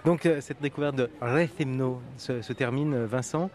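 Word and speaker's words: Rethymno: de Retimno. Rethimno. Tu y es presque, tu y es presque. Rethimno. Ah oui, j'ai oublié de, laisser traîner la, la syllabe. Rethimno. Voilà, très bien, bonne prononciation.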